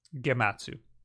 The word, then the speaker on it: Gematsu: Here's the scoop, courtesy of Gematsu. I say that right? – Gematsu.